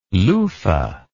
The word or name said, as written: luffa